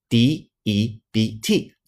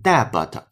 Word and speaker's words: debt: 注意这里边的 debt d e b t 字母 b 是不发音的，不能发成 debit，错了，正确读音 debt debt 私人的债务 private debt private debt 就对了。